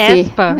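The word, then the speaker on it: aespa: Espa. – É. – Espa. – É. – é. – Espa, Espa, eu acho que no, no, na música mesmo elas pronunciam, né? Elas falam Espa.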